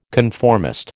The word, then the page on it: conformist: American English